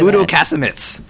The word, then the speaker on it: Udo Kasemets: How do you say Udo Kasemets.